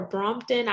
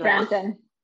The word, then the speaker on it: Brampton: So I have family in Toronto, and I think it's Brampton or Brompton. I always say it wrong. – Brampton. Yeah, I used to live in Toronto too.